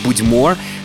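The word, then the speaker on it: Bud'mor: Ми почуємо гурт, що називається «Будьмор». Саме так потрібно читати назву цієї команди, принаймні, здебільшого саме таку я інформацію знаходив, що наголос на останній склад, що «Будьмо» вони називаються, і пишуть назву свою, до речі, англійською мовою.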